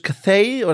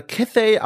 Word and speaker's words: Cathay: Und zwar dann gibt es noch die Kathai, ich spreche sie einfach mal so aus, man kann sie auch auf Englisch Cathay oder Cathay aussprechen.